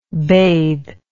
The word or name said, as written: baith